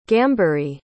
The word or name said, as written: gambhiri